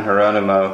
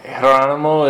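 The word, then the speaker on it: Jerónimo: And Jeronimo is with a soft J, just so you know.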